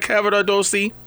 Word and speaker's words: Cavaradossi: Am I saying that right, Cavaradossi? – Yeah, you're doing a great job.